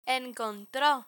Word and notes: encontro: Spanish

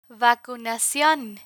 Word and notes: vacunacion: Spanish

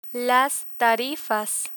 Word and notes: tarifas: Spanish

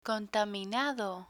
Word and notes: contaminado: Spanish

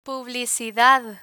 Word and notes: publicidad: Spanish

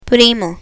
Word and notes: primo: Spanish

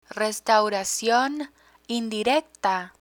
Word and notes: restauracion indirecta: spanish

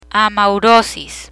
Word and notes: amaurosis: spanish

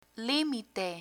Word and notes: limite: Spanish

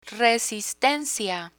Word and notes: resistencia: spanish

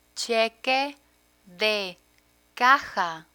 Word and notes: cheque de caja: spanish

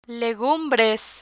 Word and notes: legumbres: Spanish

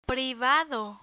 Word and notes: privado: Spanish

